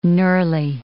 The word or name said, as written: knurly